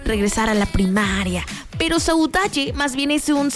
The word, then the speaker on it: saudade: Y encontré una palabra que se escribe saudade, pero se pronuncia saudade en el portugués.